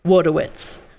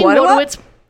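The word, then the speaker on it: Wojtowicz: So, this is the story of John Wodowitz. I'm saying Wodowitz. – Wodowitz. Wodowitz. Yes, I'm saying that because that is how he pronounces his last name.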